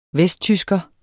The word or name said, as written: VESTTYSKERE